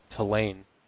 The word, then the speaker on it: Tulane: I would have been like, oh, Tulane, but Tulane is how it's pronounced.